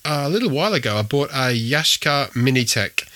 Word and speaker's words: Yashica: And yes, I pronounce it Yashica, not Yashica. I don't know where Yashica came from, but certainly all the Japanese videos I've ever watched, they say Yashica. So that's what I say. So it's a Yashica Minitec.